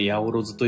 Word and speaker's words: 八百万: ヤオロズっていうのは 800 万と書いてヤオロズと読むんですが、意味は 800 万じゃなくて数えきれないほど多いっていうのが八百万です。